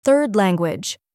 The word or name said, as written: third Languages